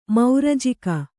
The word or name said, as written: ಮೌರಜಿಗ